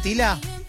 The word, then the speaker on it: Tyla: Es T-Y-L-A. – Sí, sí, la estamos viendo. – Ser Tila, entonces. – Tila. – Tila. Un jovencita. – Usted dígame mi pronunciación que yo... – ¿Es esta, Tila? – Sí.